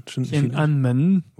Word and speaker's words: Tiananmen: Tiananmen.